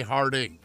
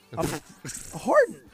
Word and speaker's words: Harden: You owe me a buck every time you say Harding. – James Harden. Harden.